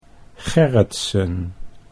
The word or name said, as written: Gerritsen